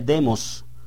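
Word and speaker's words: demos (δῆμος): Aquí aparece con acento en la E, demos, según el diccionario Strong, y que se utiliza para mencionar al pueblo reunido en forma pública, como una asamblea política, más que todo.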